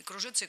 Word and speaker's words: кружится: Кстати, и то ударение допустимо, закреплено в словарях, я все посмотрела, и кружится, и кружится.